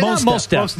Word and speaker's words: Mos Def: Now, rapper Mos Def. – Very good. – Is that how you say it? – Mos Def.